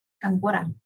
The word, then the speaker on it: Góngora: Góngora. Okay. – Yeah, G-O-N-G-O-R-A. And the first O has like the little dashy mark over it. – Góngora.